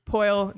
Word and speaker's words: Poile: David Poile, that's how you say his name, right? – Yep.